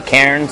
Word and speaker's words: cairn: It's a cairn. I think that's how you pronounce it correctly. Cairn, C-A-I-R-N, cairn.